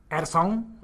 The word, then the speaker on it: Addison: I'm joined by the host with the most, Addison.